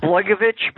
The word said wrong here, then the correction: Blagojevich: Blagovich, Blagovich, what's his name? Yeah, Blagojevich or something.